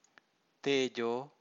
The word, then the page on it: tejo: Pali